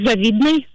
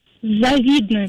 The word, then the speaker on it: завидной: Вариант данного слова с ударением на первый слог является грубейшей ошибкой. А потому акцент нужно делать только на второй слог, и говорить грамотно, как еще раз? – Завидно.